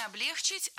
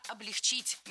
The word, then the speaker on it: облегчить: Не облегчить, а облегчить. Не облегчили, а облегчили. Не облегчит, а облегчит. В глаголе «облегчить» и во всех его формах ударение ставим всегда за корнем, а корень оставляем безударным. Облегчим, облегчишь, облегчила.